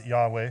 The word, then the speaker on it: Yahweh: We pronounce it Yahweh. If you're ever around a Jew, don't you dare say that. You will offend them to no end. And we don't really even know how they pronounce it. That's what we think. It's our best guess.